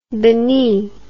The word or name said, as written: bini